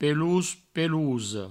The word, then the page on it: pelós, pelosa: pelous, pelouso; provencal